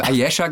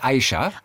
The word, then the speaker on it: Ayesha: Ich habe Ayesha gesagt, aber es ist Aisha. – Alle sagen Ayesha, weil das würden wir so betonen. – Ja, weil A-Y-E-S.